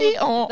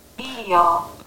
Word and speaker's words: Eeyore: We've got a Google Eeyore that can pronounce it. Let's see what she says. How do you play it? Oh. – Eeyore.